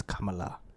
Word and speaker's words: Kamala: As a proper fucking Indian, it's Kamala. Not Kamala.